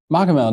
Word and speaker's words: Machamehl: Machamehl.